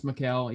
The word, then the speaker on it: Michael: I mean, I think it's pronounced Mikhail, even though it's spelled out Michael.